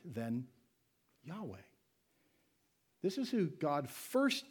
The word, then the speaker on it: Yahweh: It's pronounced Yahweh if you have to pronounce it.